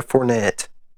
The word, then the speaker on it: Fournette: How do you say his name again? Andre Fournette. – Fournette. – Or Fournette.